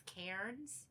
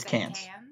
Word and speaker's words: Cairns: Which it's like, if you look at it, it looks like it's supposed to read as Cairns, but nobody pronounces R's in Australia. – Okay, so it's Cairns.